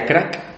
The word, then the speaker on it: Krack: Ha sido la zapatería Crack,